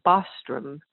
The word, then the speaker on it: Bostrom: But after I married, my last name became Bostrom, which is spelled B-O-S-T-R-O-M.